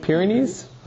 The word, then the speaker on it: Pyrenees: Arianism was initially the dominant form of Christianity in Spain, the Pyrenees, did I say that right? – Pyrenees, okay.